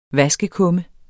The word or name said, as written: vaskekumme